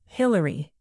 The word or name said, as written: Hilary